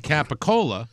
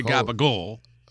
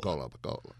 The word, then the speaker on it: capicola: But you say capicola. You don't say gabagol. – Gabagol. – You say capicola. – Capicola.